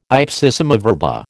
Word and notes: ipsissima verba: American English

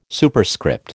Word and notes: Superscript: American English

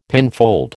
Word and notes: pinfold: American English

